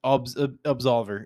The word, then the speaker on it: Absolver: But yeah, no, Absolver, if you've never heard of it and you like fighting games, check it out. You spell it A-B-S-O-L-V-E-R. So it's Solver, but A-B at the beginning. So Absolver.